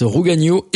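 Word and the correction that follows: Ragougneau: Évangile pour un gueux. – Ragugno. – Ragugno, pardon. Oui.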